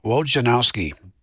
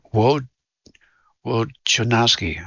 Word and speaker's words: Wojnarowski: This is Bob Wojnarowski. I'm butchering his name. Wojnarowski.